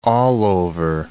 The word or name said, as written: allover